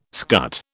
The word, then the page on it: scut: British English